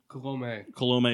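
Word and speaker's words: Colomé: And then for my team, pitching rotation is Scherzer and Verlander with Alex Colom. At the closer, is it Colom? – Colome. – It is Colome. It's a little accent on the E.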